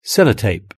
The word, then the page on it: sellotape: British English